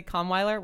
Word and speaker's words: Kahnweiler: And we're talking with Jessie Kahnweiler. We're saying your name right. – Yeah. – And you can find her on Twitter at Jess Kahnweiler. That's Kahnweiler.